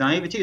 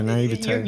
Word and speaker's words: naivety: Is naivety, is that how you say it? Yeah. because yeah, naivete.